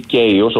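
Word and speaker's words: Kaos: Η σειρά Κάος ή Κέιος, όπως θέλει κανένας την προφέρει.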